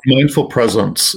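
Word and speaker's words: Mindful Presents: Is that how you pronounce it, or yes, present? How do you prefer? – Mindful presence. It's like a gift.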